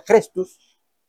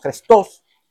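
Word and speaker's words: Chrestus: Suetonio opinaba que la orden estaba relacionada con un hombre llamado en latín Crestus, o su pronunciación aproximada sería Crestus o Crestos.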